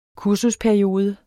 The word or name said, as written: kursusperiode